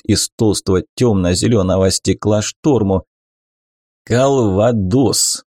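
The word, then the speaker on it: Кальвадос: «У нас нет буквы, обозначающей смягчение согласной, а так-то было бы правильно произносить «калвадос» через «л», а не «л».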